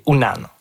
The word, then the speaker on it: unan: Puis le vrai mot inou, c'est unan, ou parfois prononcé wanan.